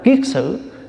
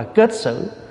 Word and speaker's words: kiết xử: Cho nên gọi là phiền não là cái mà nó sai xử mình, gọi là xử, là cho nên gọi là kiết xử. Chữ kiết là cột, chữ xử là nó sai mình. Có khi mình đọc là kết xử.